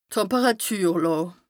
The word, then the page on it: Temperàtürlàà: Bas Rhin